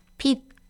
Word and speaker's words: 핏: These are going to be words that you already know in English, and they are just going to be pronounced in the Korean pronunciation. So here are a few. So first of all, 핏. – 핏.